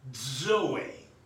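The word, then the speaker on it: Zoe (ζωή): Zoe. It's spelled Z, we look at Z-O-E, but it's pronounced Zoe.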